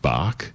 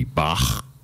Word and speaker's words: Bach: He said you can't pronounce it Bach, like the bark on tree. It has to be Bach.